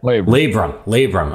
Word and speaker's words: labrum: He's got a torn labrum. How do you say that? – Labrum. – I'm missing the R.